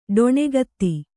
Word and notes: ಡೊಣ್ಣೆಕೇತ: ḍoṇṇe kēta

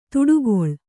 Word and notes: ದಾಟುಗಾಲು: Kannada

